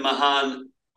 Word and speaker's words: Mahan: And what responsibility, if any, do you take in this, frankly, debacle at Edna Mann, please? – It's hard to spin anything at Edna Mahan over the years. – I apologize for it's Mahan, yeah, right?